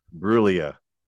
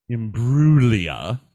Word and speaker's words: Imbruglia: Brulia. There's no G. We went over this last time. – There is a G. – It's not pronounced with the G.